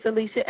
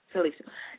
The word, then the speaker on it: Syleecia: But before we get into it, Dr. Syleecia, am I pronouncing your name right? – It's Syleecia. Yeah, you got it right. – Syleecia.